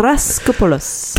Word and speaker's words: Raskopoulos: I can never pronounce his name. Raskopoulos.